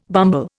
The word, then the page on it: bumble: Indian